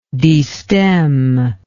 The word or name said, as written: destemmer